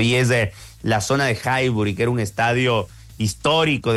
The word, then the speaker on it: Highbury: Estaba en proceso de levantar planos y más, porque ahí se jugaba en un estadio que se llamaba Highbury.